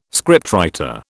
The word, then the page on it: scriptwriter: British English